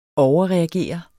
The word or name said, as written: overreagere